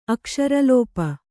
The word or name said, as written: ಅಕ್ಷರಲೋಪ